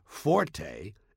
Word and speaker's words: forte: The Italian word forte, F-O-R-T-E, used in music notation, is pronounced forte, and it instructs the musician to play loud.